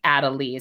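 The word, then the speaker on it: Adalee: So I kind of kept veering towards Adele while I was reading it, but I think it's actually Adelie is how you're supposed to say it.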